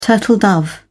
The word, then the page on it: turtle dove: British English